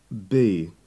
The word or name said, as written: be